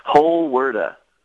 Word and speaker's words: Holwerda: Holwerda. – Holwerda. – Like you're pronouncing the whole word? Holwerda? – Holwerda.